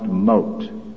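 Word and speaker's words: mot: That word "moved" is from the Hebrew word they spelled M-O-W-T, and according to Strong's, it's pronounced "mote."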